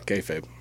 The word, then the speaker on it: kayfabe: I really like how you said KFAT. That's a wrestling thing. – Is that how it's said? – Yeah, kayfabe.